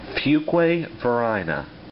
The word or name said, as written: Fuquay-Varina